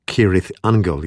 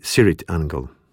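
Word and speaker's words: Cirith Ungol: Poprawna wymowa nazwy to Kirith Angel, jednak członkowie zespołu wymawiają ją z miękkim C na początku, czyli Sirith Angel.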